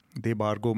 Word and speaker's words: देबार्गो: देबार्गो मुझे लगता है कि मैं सही बोल रहा हूँ क्योंकि देबार्गो ने खुद भी लिखा था कि इसको एग्जैक्टली कैसे प्रोनाउंसिएशन इसका होगा